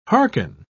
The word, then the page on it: harken: British English